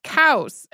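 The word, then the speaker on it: kaos (χάος): It's pronounced kaos. And the way Greeks described something that was before the existence of Gaia and Ouranos, or the earth and the sky, and it would definitely sound like cows if you'd never heard it before.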